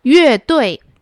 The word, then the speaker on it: 乐: Next one, 乐队乐队 A band, or music band, right? – Right. And the character 乐 here is pronounced 乐, not 乐, because it's from the word 音乐, music.